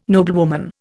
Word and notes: noblewoman: Indian

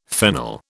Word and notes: fennel: British English

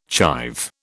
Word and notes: chive: British English

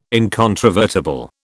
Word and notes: incontrovertible: British English

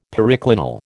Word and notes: periclinal: American English

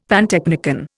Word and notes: pantechnicon: Indian

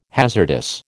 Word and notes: hazardous: American English

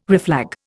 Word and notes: reflag: Indian

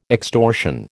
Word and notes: extortion: American English

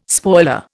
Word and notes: spoiler: Indian

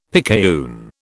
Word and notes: picayune: British English

